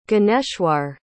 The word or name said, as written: Gyaneshwar